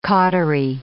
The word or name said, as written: cautery